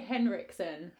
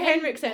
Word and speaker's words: Henriksen: Ole Henriksen. – Henriksen, isn't it? – No, there's no D in it. – Oh. Okay. I can't say it. Oh, Ole Henriksen.